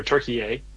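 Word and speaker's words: Türkiye: and just learn a ton about Turkey, or Turkey A, as the proper pronunciation.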